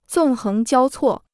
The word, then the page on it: 纵横交错: zòng héng jiāo cuò